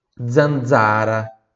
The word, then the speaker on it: zanzara: Zeta, zaino, zero, zanzara. Tanto no primeiro quanto no segundo Z aqui, pronuncia dolce, zanzara, ó, oh, como se fosse oh.